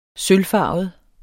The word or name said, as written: sølvfarvet